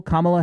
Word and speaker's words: Kamala: Kamala Harris. I pronounced that right, didn't I? – Yes, you did.